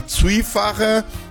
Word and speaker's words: Zwiefacher: Então, vamos com música, começando agora com Rote Haniot, que é uma Zwiefacher, mistura de valsa com dria. Falei certo agora também? – Sim.